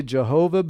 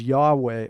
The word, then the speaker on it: Yahweh: No one knows how to pronounce it. Some people say Jehovah, but it can't be that because there's no J sound in Hebrew. So I believe the pronunciation is Yahweh or Yahweh.